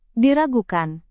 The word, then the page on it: diragukan: Indonesian